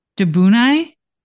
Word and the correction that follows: Dobunni: Mm-hmm. I think that's right. – It's Dobunni.